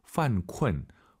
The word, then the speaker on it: 犯困: 犯困, 犯困 이 발음을 잘하셔야 됩니다. 쿤쿤 하지 마시고 유엔 사이에 으어 들어가서 다시 한번 犯困.